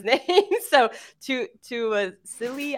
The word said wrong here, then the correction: Tassili: Akana, is that what it is? – Tassili Akana. Sorry. – Tassili. Yeah. Tassili.